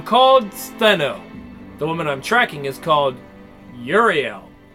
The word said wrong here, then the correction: Ureli: And I said it wrong again, didn't I? Ureli.